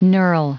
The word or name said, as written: knurl